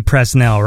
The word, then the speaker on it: Presnell: How do you say that last name? P-R-E-S-N-E-L-L. – Presnell. – Is it Presnell? – Presnell. – Presnell? Jonathan Presnell? Well, here we go. All right, I'll say Presnell.